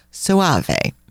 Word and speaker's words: Soave: and that is spelled S-O-A-V-E. Not like, who was Enrico Suave? Remember that? – Yes. – So this is pronounced Suave.